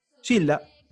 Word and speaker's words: Gilda: Sí, total, porque justamente ahí estabas hablando de, ¿se pronuncia Gilda o Gilda? ¿Gilda? Gilda. Ok, era lo único que había en cumbia.